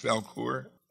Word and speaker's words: Valcour: That's how it's pronounced, Valcour? – Valcour, yeah, I guess that's a small island that's just south of what's now Plattsburgh.